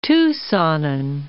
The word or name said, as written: Tucsonan